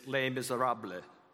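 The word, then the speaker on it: Les Misérables: But the French pronunciation is Les Miserables, bleh.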